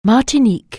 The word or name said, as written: Martinique